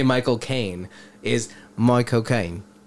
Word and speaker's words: Caine: And the way to say his name is to say my cocaine.